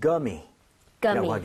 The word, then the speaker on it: gummy: 그런 것을 이렇게 표기하는데, 대신에 우리는 굼이라고 하는데, 영어에서는 gummy라고 하게 Gummy. – 됩니다. gummy, right? So, 구가 아니라 거에 가깝게 발음을 하셔야 됩니다.